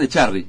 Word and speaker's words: Echarry: el señor Sebastián Echarri, ¿lo digo bien? – Sí, muy bien.